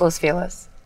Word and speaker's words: Los Feliz: And it's pronounced Los Feliz. – I know. What did I just say? – Los Feliz. – Damn it. I know, but that's... – You didn't just move here, or I would have let it go. – But it is. That's how you correctly pronounce it in Spanish.